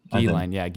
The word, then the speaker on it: Ghislaine: What the documentary does a good job of is just outlining the vast number of people that Epstein and Ghislaine, or Ghislaine, is that how you pronounce it? – Ghislaine. – That sounds right.